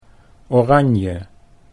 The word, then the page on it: oranje: Dutch